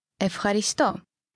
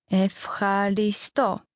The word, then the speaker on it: ευχαριστώ: In Greek, thank you is ευχαριστώ. Ευχαριστώ. Let's break it down by syllable. Ευχαριστώ. Now let's hear it one more time.